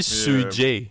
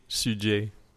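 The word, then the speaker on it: Suge: Ça se dit Sujay. Euh, Sujay.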